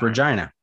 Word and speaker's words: Regina: Like Queen Regina, but it's got it. It's pronounced Regina.